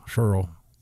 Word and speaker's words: Scurll: Rhymes with girl, Scurll.